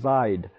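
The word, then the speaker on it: Zeid: It's a Z-E-I-D, but it's pronounced Zied.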